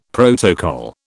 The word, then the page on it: protocol: British English